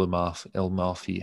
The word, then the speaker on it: Amalfi: It's 2 syllables. So, you know, Amalfi. It's 3 syllables.